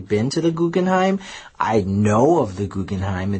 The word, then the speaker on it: Guggenheim: Tour the Guggenheim? Did I say that right? – Mm-hmm, Guggenheim.